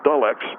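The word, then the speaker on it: Delux: No, it's D-E-L-U-X, but it's pronounced Dull X.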